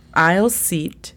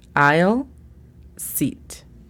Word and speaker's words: aisle seat: Aisle seat significa asiento de pasillo. Es el asiento que nadie quiere. – Sí, tal cual. – La pronunciación fonética es A-I-L-S-I-T. Aisle seat.